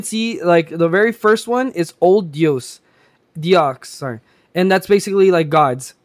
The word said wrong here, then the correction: Deus: I think you mispronounced Deus Ex. – Yeah, it's Old Deus.